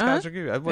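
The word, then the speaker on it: Kajagoogoo: It's Kaja Gugu.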